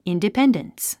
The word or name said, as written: independence